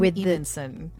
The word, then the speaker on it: Edenson: It's spelled strangely, but it's Edenson.